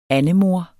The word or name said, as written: andemor